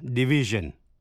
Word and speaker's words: division: Những danh từ tương đương là division, chữ S đọc là J, decision, evasion, invasion, erosion và conclusion.